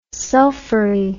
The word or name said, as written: sulfury